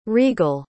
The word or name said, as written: reigle